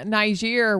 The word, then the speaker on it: Niger: And the people in Niger were so...